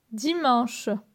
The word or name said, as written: Dimanche